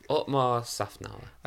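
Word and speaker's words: Otmar Szafnauer: No, Otmar Safnauer.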